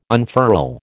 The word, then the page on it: unfurl: American English